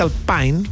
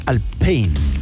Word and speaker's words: Alpine: Pero yo pero lo dicen mal porque la pronunciación real es más Alpine, no como Alpine. – Claro. – No es Alpine, es anglo. – No, es francés, po.